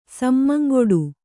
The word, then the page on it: ಸಮ್ಮಂಗೊಡು: kannada